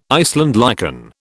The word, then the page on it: Iceland lichen: British English